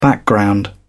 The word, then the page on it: background: British English